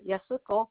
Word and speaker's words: Yasuko: Okay, I'm going to spell because Y-A-S-U-K-O. Yasuko. Baba's like last name is Baba, like Baba Shop. B-A-R-B-B-R. – B-A-R-B-B-R. – B-E-R.